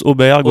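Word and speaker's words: Åberg: Ouais, une, une, équipe européenne avec euh, le, tout jeune euh, Aberg, on dit Alors il paraît que ça se prononce Auberg.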